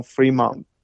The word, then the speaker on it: Fremont: 对，还记得 Fremont 是个法文，所以他既然是法文，最后的 T 本来是不发音的啊。嗯，呃，所以我们很多人就直接把它念成 Fremont，那个 T 不念出来，其实是对的。